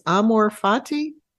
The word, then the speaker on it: amor fati: and it's not and i don't know if i'm going to pronounce this correctly the latin phrase amor fati uh, um, perfect that, okay yeah.